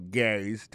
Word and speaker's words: Gary: It's time for Gary's Tips. Regardez où il a prononcé le nom Gary. Alors, accent tombé sous le premier syllabe.